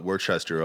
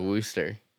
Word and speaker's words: Worcester: It's just, it's because I feel like there's a lot more hoops you got to go through to get to Worcester than colonel. – It's only because the pronunciation is what I'm thinking. – The RCE becomes just one O.